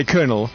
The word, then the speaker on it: colonel: I suppose because it's spelled colonel. – Yeah. – You say colonel.